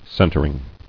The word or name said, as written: Centering